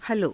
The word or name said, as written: halo